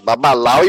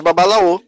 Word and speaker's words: babalaô: É, e Babalau é uma palavra, é uma, é uma pronúncia espanholada de babalaô. Né? É, lá em Cuba, se for falar com um cubano, ele vai dizer babalau, babalau. É, é a pronúncia que, que, que surgiu de babalaô, né? que, que, que, que, brota da língua a partir da palavra iorubá babalaô.